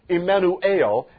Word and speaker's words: Emmanuel: Now, Emmanuel, the word is actually Emmanuel. Emmanuel, that's how you pronounce it in the Greek, and it literally means God with us.